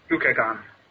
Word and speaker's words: glucagon: Or I think it's pronounced glucogen. I really don't trust it. – Glucagon.